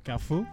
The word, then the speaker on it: Golfue: Golfu. It is pronounced, actually spelt G-O-L-F-U-E.